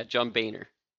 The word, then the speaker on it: Boehner: Yeah, John Boehner. – Oh, sorry. – But it's spelled looking like Boner.